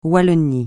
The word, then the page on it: Wallonie: francais